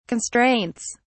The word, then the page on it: constraints: British English